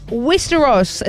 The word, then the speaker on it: Westeros: Westeros is the fictional continent in the House of Dragon and Game of Thrones.